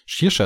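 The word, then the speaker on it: Saoirse: Shirsha ist übrigens ausgesprochen, so ist das Rätsel mal gelöst.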